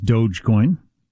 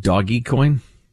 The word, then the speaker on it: Dogecoin: Dogecoin is Dogecoin. Okay. Thank you for that. – Not Doggy Coin.